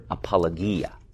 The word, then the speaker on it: apologia: It's the Greek word apologia. Apologia. That, yes. – Can you spell A-P-O-L-O-G-I-A. And logia, I was thinking there was a long O in there. That's apologia. – Is that just like the book company? – Yes. Except they pronounce it apologia because they speak English, which is fine.